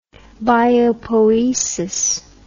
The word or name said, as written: biopoiesis